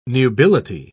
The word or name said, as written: nubility